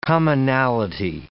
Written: commonality

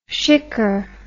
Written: shikker

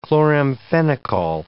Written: chloramphenicol

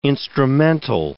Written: instrumental

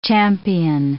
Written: champion

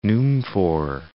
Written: Numfoor

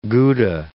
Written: Gouda

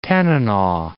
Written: Tanana

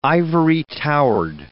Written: ivory-towered

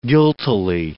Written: guiltily

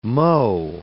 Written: mho

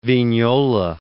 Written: Vignola

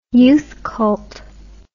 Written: youth cult

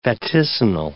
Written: vaticinal

